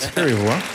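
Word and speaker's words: Skerryvore: Well, it's nice to hear you say it, because now we know how to say it. Scary Vore.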